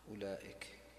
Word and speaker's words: أولئك: أولئك، وين الواو؟ ما عندي واو، الواو هذه لا تقول.